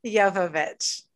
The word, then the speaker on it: Jovovich: Like I can never say Mila Johovich. – Johovich, huh? I, I Jovovich.